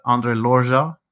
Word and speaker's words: André Loja: There's a governance board and an advisory board, and on the governance board is André Loja, André Loja, that's the correct pronunciation, who's the native Madeiran who started the whole thing.